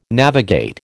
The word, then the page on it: navigate: American English